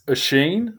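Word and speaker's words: Oisin: It's Oshin. So it's Oshin. Like, it's not it's Ocean. – O- It's not ocean.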